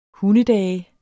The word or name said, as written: hundedage